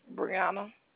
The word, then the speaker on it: Breunna: My name is Brianna. – Brianna? Could you spell that for me just to make sure? – B-R-E-U-N-N-A.